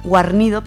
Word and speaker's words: Guarnido: Es con guión de Teresa Valero y dibujos de Juanjo Garrido, Guarnido, perdón, hoy siempre lo digo mal, que es conocido por hacer la serie Black Sad.